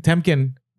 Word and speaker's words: Temkin: They were willing to admit though, like what was awesome about uh, the VP, uh, Harry Kempin? Did I say his name? Temkin. Temkin, yeah. Okay.